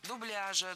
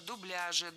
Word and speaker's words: дубляж: Дело в том, что совсем недавно за этим словом было закреплено неподвижное ударение на втором слоге. Дубляжа, дубляжа, дубляжем. Но так никто не говорил. И филологи сжалились, ввели двойную норму в этом слове. Теперь оба варианта, и дубляжа, и дубляжа, являются литературными.